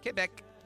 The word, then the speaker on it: Quebec: Finally, a quiet, trophy-free moment of appreciation for the groundbreaking work done recently in the Canadian province of Quebec, or as the locals say it, Quebec, by Superior Court Judge Andres Guerin, who finally handed a group of non-traditional families equal justice before the law.